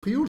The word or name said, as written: frioul